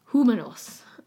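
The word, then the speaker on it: Jumanos: But this Mary, she reported that she was often transported by the aid of angels to settlements of a people called the Humanos. It's spelled with a J. I'm just going to say that's an H pronunciation, though, because it's in the New World.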